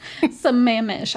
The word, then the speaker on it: Sammamish: Sammamish, I believe is how you say it. It's Sammamish.